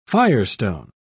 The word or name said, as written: firestone